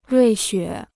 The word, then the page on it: 瑞雪: ruì xuě